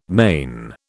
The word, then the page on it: mane: British English